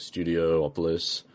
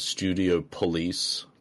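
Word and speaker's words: Studiopolis: I thoroughly enjoyed, like, Studio Opolis. Or as JT would probably pronounce it, Studio Police, because, you know, he pronounces stuff weird.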